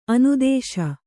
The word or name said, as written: ಅನುದಾಸ